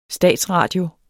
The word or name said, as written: statsradio